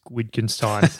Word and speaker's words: Wittgenstein: But then again, if you think about this quote by Ludwig Wittgenstein, he said, the limits of... – So I think the W is like a V pronunciation, we'll but that's all right.